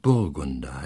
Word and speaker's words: Burgunder: Ich sage gleich mal in Parenthese, die germanische Anfangsbetonung fordert auch im Deutschen, dass die Namen auf der ersten Silbe betont werden, auch wenn es sich nicht nur um zweisilbige, sondern um dreisilbige Namen handelt. Also Burgunder heißt es, Nibelungen heißt es, Walküre heißt es und nicht, ich verweigere mich der falschen Betonung auf der falschen Silbe.